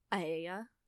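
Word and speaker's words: Aeaea: It's literally spelled it's spelled A-E-A-E-A. I'm just like, what in the world? I couldn't figure out the pronunciation without looking it up. So that's how you pronounce it, if you're wondering.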